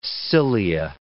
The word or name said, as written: cilia